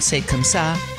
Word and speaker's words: Say Comme Ça: So I pronounced it wrong. Say comme ça. Say, not C-A, I thought it was California. Say comme ça.